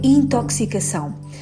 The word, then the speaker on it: intoxicação: A pronúncia correta é intoxicação. A consoante X da palavra intoxicação articula-se que se, uma vez que na sua origem está o adjetivo tóxico. Este adjetivo provém do grego tóxicos, pronunciado originalmente com o som que se.